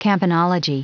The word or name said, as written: campanology